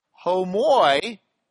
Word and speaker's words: homoiousios: Just stick one of those right in there, an iota. You don't dot the I in Greek, sorry. An iota. So instead of homoousios, it would be pronounced homoiousios.